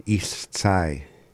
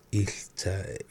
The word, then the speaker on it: Ishtzai: Yeah, Ishtzai. That's how you say it, pick berries. – Ishtzai.